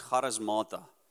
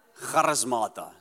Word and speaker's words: Charismata: It's a Greek word, so it's how you need to pronounce it like you pronounce it in Afrikaans, Charismata. The world's not English, by the way. It's Charismata. Say Charismata.